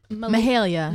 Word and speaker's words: Mahalia: Mahalia.